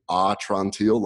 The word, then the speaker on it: Atrantil: So the name is a little difficult to say, and there's a whole story behind that, but it's actually Atrantil, like, my belly's better.